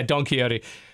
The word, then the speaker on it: Don Quixote: And also I can never pronounce it, Don Quixote? – Is it really that? I am that shit. Quixote? Wow, yeah, Don Quixote.